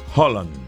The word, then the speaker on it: Haaland: En noruego esa doble A es una doble O. Es Haaland.